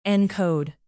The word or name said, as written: encode